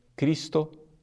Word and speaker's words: Christo: Ao invés de Cristo, como nós falamos em português, deve se pronunciar Cristo.